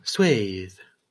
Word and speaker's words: Swath: So the first result for pronounce Swath has the audio pronunciation, and when I clicked it, said Swath.